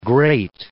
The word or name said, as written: great